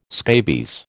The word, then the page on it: scabies: American English